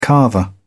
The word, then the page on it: carver: British English